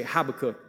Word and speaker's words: Habakkuk: So I want to invite you this week and, and whenever this arises, like write down this passage from the prophet Habakkuk. Write it down. Or you say Habakkuk. They, we, you pronounce it both ways. It's all good.